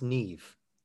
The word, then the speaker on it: Niamh: So it's pronounced, or it's spelled N-I-A-M-H, and it's pronounced Neve. – That's right. It's Neve.